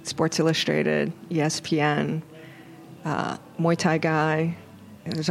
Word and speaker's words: Muay Thai: Tell Muay Thai. – Me. – Muay Thai. Very close to Mai Thai.